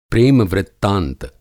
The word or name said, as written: प्रेम-वृत्तांत